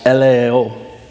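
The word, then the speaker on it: eleo (ἐλεέω): And the Greek word for mercy is pronounced eleo.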